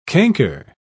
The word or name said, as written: canker